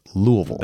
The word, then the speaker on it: Louisville: So the way you accurately pronounce that city in Kentucky is Louisville. – That's right. – Louisville. Mm-hmm. That's the correct pronunciation.